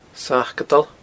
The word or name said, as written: Sacadail